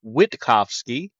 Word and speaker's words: Wittkofsky: My name is Tyler Wittkofsky. I tell people it's three words, wit, cough, and ski.